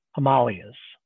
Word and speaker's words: Himalayas: But the reality is what you see beyond New Delhi in the background are the, I'm told the correct pronunciation is the Himalayas.